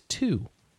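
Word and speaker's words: to (と): The word and in Japanese is to, pronounced like toe.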